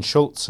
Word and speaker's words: Schultze: Practices for Renewing Christian Higher Education by Quentin Schultze. And if you're using search engines, that's spelled S C H U L T Z E.